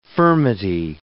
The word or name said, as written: furmity